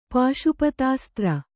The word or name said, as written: ಪಾಶುಪತಾಸ್ತ್ರ